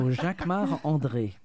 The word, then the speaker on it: Jacquemart-André: Jacquemart-André. Jacques, you say it like André. A Frenchman. – Jacques of course, André. Because I'm French. So Jacquemart André.